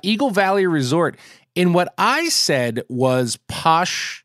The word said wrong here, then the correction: Pioche: Nevada. And that was supposed to be pronounced Pioche. I still don't really know how to pronounce it. Pioche. That's what it is.